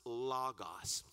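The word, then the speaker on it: logos (λόγος): Now that word in Greek is pronounced logos.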